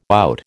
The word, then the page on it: out: American English